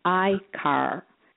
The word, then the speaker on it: AKR: Yes, trust has the three letters, which is AKR, pronounced I-car. They're very fascinating because their names end up like the first one, E-car. This is I. The pronunciation is A-Y-K-A-R. The letters are A-K-R,